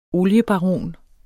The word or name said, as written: oliebaron